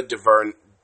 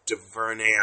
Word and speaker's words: DuVernay: But black women specifically, everyone's talking about Ava DuVernay, DuVernay. I mispronounced that.